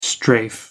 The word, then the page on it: strafe: American English